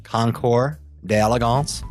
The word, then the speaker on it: Concours d'Elegance: That is the correct pronunciation, Concours d'Elegance.